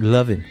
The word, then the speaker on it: Louvin: That was the Lauvin brothers. Apparently you pronounce it Lovin.